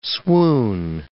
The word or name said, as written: swoon